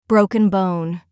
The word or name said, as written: broken bone